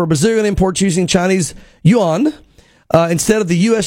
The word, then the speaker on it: Yuan: So apparently it's pronounced Yuan. And that's how you say it.